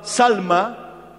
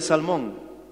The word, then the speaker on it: Salma: Y uno de esos espías se llamaba Salma, o también se pronuncia Salmón.